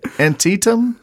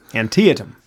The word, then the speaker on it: Antietam: Antietam? – Antietam.